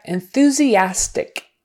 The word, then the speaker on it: enthusiastic: So that EN is more like an IN, more like a short I silver. En- enthusiastic. Um, you could say it enthusiastic, but in real life conversation, it's more like a eh.